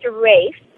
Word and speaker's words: Ralph: Now, there was a special dog in your life, and his name is spelled Ralph, but you pronounce it differently. – It's pronounced Rafe.